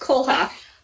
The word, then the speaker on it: Kohlhof: Did I pronounce that right, Michelle? – Almost. Kohlhof. – Kohlhof, thank you.